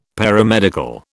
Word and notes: Paramedical: British English

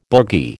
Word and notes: boogie: American English

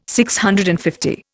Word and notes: DCL: Indian

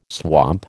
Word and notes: swamp: American English

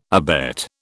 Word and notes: abet: British English

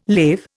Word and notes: lave: Indian

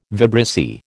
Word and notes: vibrissae: American English

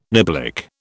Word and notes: niblick: British English

